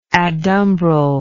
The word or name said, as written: adumbral